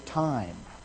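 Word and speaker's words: time (τιμή): It's the Greek word spelt T-I-M-E, pronounced time.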